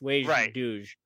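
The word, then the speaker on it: Wej Duj: But I think Wage Duge, is that how do you say that? – Yeah, it's Klingon for three ships. Wage Duge.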